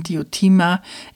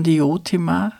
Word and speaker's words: Diotima: Diotima, im Altgriechischen betont man Diotima, im heutigen Deutsch spricht man meist von Diotima, man kann also beides sagen, berichtet von der armseligen Zeugung des Eros am Rande eines Festes.